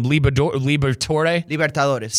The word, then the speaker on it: Libertadores: Libertadores.